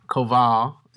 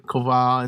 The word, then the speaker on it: Koval: Koval. Did I say it right? – Yeah. – Koval.